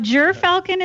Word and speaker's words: Gyrfalcon: Okay, so I always pronounced it Gerfalcon, which I've learned is not, or Gyrfalcon, rather, which I've learned is not Correct. Not that it matters. But jeer falcon is good.